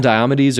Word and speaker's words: Diomedes: Diomedes. – Diomedes. – Or how'd you just say it? You can call him Diomedes or Diomedes.